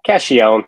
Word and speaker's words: Cacchione: Am I saying your name right? Caccione. – Caccione. It was close enough, Caccione. Though. I've heard worse.